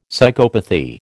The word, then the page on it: psychopathy: American English